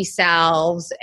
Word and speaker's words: salve: So I use things like tinctures and full CBD salves and or salves. Sorry, I just recently found out that it's pronounced salve instead of salve.